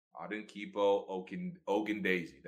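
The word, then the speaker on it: Adetokunbo Ogundeji: But I want everyone in Atlanta to know how to say Adinkipo Okendeji. – Adinkipo Okendeji. That's how you say it.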